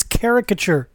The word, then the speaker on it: caricature: Why can no one pronounce it? It's caricature.